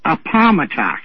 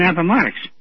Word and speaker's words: Appomattox: A-P-A-L-A-P-A-L-M-A-T-O-X. – Appomattox? – Appomattox. To me, it's always been Appomattox. – Yeah. – And I said, and they pay somebody to read this news? – Well, he just had his emphasis on the wrong syllable.